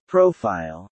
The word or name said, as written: profile